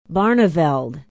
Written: barneveld

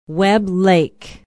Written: webb lake

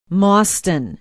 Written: Mauston

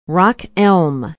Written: rock elm

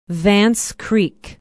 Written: vance creek

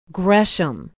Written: gresham

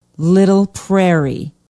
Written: Little Prairie

